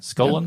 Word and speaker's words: Scullin: B, James Scullion. I think that's how you say it. Mm-hmm. D, John Curtin. Prime Minister was sworn on this day in 1929? Robert Menzies, James Scullion, S-C-U-L-L-I-N, Scullion, yep.